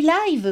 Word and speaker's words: live: Oui, je sais, ça s'écrit live, mais ça se dit live, maman.